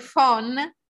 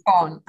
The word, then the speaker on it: phon: We spell it as if it were English, even though it's not. It's P-H, which is not an Italian letter cluster. No. P-H-O-N, phon. We pronounce it phon.